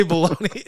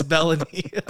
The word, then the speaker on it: Belloni: It's spelled like you could say Belloni. It's Bellany. I always say Belloni in my head.